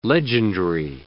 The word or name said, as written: legendry